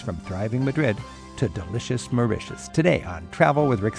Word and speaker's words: Mauritius: Learning more about this island getaway, it just seems right that Mauritius rhymes with delicious.